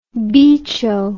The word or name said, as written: bicho